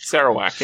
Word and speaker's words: Sarawak: the Rene Brook of Sarawak, or however it's pronounced. – Sarawak, yep. You got it.